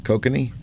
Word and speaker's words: kokanee: This regular goes out there, and he's casting for what he thinks it's kokanee. Did I say that right? Okay. – Yeah.